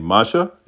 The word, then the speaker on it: Masha: Masha, is that correct? – Yeah, good.